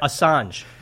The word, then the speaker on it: Assange: Assange? – You know who I'm saying. – Yeah, but it sounds like you're saying massage. – No, I'm not. How would you say it? – Assange.